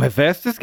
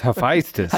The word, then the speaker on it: Hephaestus: You damn Hephaestus, get over here now. It's Hephaestus.